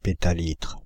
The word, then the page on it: pétalitre: [pe.ta.litʁ]; /pe.ta.litʁ/